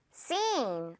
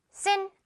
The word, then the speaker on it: scene: 발음은 scene, 어렵지 않죠? 듣고 큰 소리로 따라해 주는데요, 입을 양옆으로 좀 찢어서 길게 늘어뜨려서 발음을 해주세요. 바로 갑니다. scene. 그렇죠. sin 아니고요. sin 길게.